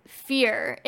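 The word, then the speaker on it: Fier: Fear. Instead of F-E-A-R, it's F-I-E-R. – Yeah. – Like, which, same pronunciation, but that's why I'm just like...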